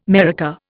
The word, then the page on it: maraca: Indian